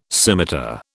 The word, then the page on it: scimitar: British English